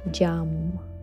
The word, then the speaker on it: Yam: En este vas a repetir el mantra Yam, Yam, Yam. ¿Cómo se escribe? Y-A-M.